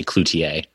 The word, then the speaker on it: Cloutier: There's been some wild ones, but Cloutier or Cloutier, I usually just say Cloutier. – And that's the that's the Canadian in you with the French pronunciation. – Yeah, that's the French Canadian side.